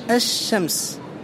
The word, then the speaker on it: الشمس: وهل تكتب هنا اللام؟ لا تكتب اللام. لماذا؟ أحسنت، لأنك لا تنطقها، لأن ما لا ينطق لا يكتب. إذن تكتبها همزة ثم شين ساكنة ثم شين متحركة ثم ميم ثم سين. قال: ويلاحظ أن اللام الشمسية لا تكتب لأنها لا تنطق.